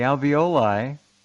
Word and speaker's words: alveoli: The alveoli, did I say that right? – Yeah. – Yeah, good.